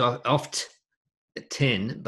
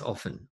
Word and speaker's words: often: Yeah, actually, in Australia, we don't pronounce the T often. Often. I know it says oft 10, but it's not oft 10. It's not even off 9, it's often, O-F-E-N. Well, it's pronounced, the T is silent.